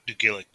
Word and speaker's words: Dugalic: And same for Angela Dugalic, or how do you pronounce her last name? Do you know? – Dugalic, yeah.